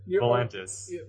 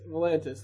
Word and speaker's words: Volantis: You might have, yes, and there are sea serpents off of Valonqor, or whatever, I forget the name. I said that wrong. – Volantis. – Volantis, thank you.